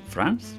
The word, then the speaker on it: Franz: Bueno, el señor se llama Fran. – Franz. – Franz.